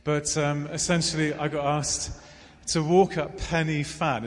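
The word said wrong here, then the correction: Pen y Fan: Is that how you pronounce it, Owen? Penavan.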